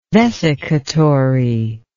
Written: vesicatory

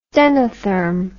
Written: stenotherm

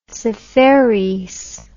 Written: Seferis

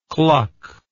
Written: kluck

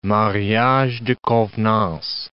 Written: mariage de convenance

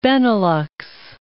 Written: Benelux

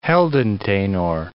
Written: heldentenor

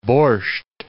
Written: borscht

